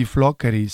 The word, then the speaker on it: Floccari: Parce que moi, j'ai toujours aussi du mal à le prononcer Floccari. Floccari. – Floccari. – En italien, c'est l'accento tonico. Sur le O, donc on dit Floccari.